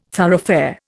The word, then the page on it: thoroughfare: Indian